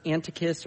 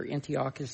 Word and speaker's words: Antiochus: A specific leader, the Greek ruler Antiochus, or Antiochus, some people pronounce his name different,